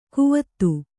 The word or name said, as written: ಕುವತ್ತು